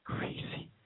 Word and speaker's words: Creasy: Creasy. You know, rhymes with greasy.